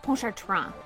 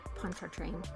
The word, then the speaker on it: Pontchartrain: Jérôme de Pontchartrain is how they would have said it. But you can say Pontchartrain. That's fine. – Pontchartrain. – Pontchartrain. – Because that's how they say it down in Louisiana.